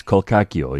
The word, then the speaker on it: Colcacchio: Yes, that is how you pronounce it. I'm very, very excited because these are... – Just throw in that disclaimer. – Well, because people don't know. Then they say to me, no, you pronounce it Colcacchio. You don't, all right? Trust me, it's Colcacchio. It's Italian.